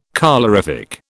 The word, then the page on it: colorific: British English